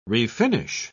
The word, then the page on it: refinish: British English